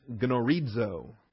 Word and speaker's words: gnorizo: The verb here is gnorizo, or norizo if you insist on the silent G. Norizo. G-N-O-R-I-Z-O.